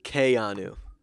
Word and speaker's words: Keanu: And apparently, according to Wikipedia, it's pronounced Keanu.